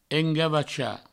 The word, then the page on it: engavachar: engavacha